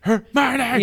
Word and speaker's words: Hermione: I was like, it's Hermione. – Yeah, because it's like, oh, that's Herman, but yeah.